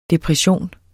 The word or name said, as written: depressionen